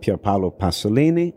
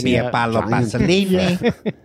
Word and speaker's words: Pier Paolo Pasolini: Well, it deals with the last couple of days in the life of Pier Paolo Pasolini. – I like the way he said that. – Good pronunciation. – Pier Paolo Pasolini.